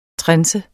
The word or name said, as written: trense